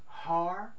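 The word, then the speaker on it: har (הר): The word is pronounced har in the Hebrew language.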